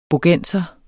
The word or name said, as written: bogenser